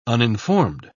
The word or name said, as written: uninformed